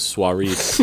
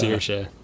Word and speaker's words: Saoirse: Is that how you pronounce her name? I always thought it was Suarez. – Suarez. No, dog. Oh, the real Ronan heads out there now. It's It's Saoirse.